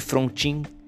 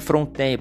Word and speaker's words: Frontin: Mas é conhecido como Paulo de Fronten ou Paulo de Frontin, se você for ler no na fonética mesmo, né? Se escreve Frontin. Mas eu tenho por mim que é Fronten, porque ele tinha uma origem acho que francesa.